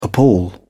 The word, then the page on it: appal: British English